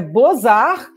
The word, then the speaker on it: Beaux-Arts: Aí a minha amiga falou, não, é Bozar, que é essa coisa, né, erudita. – Belas Artes belas artes, escrito, né? Que o O em francês pode se escrever de 30 maneiras diferentes, né?